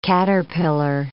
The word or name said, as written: caterpillar